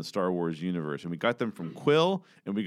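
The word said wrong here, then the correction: Kuiil: got them, or Queel, I should say. It's not Quill, it's Queel. Queel.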